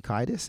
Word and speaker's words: Kiedis: And Anthony Kiedis, is that how you pronounce his name? – Yeah.